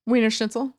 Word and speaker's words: Wiener Schnitzel: Wiener Schnitzel.